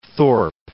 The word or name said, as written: Thorpe